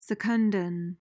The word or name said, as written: secundan